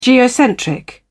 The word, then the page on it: geocentric: British English